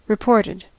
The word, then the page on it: reported: American English